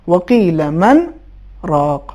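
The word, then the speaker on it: وقيل من راق: اسی طریقے سے ایک لفظ اور بھی ہے سورہ قیامت آیت نمبر 27 میں، وقیل من راق، نون ساکن ہے اس کے بعد حرف ر ہیں، قاعدے کے مطابق یہاں پہ ادغام ہونا چاہیے لیکن چونکہ حرف نون پر سکت ہیں لہذا دونوں کو الگ الگ تلفظ کریں گے اور ادغام نہیں ہوگا. صحیح تلفظ یہ ہے، وقیل من راق.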